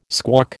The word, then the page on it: squawk: American English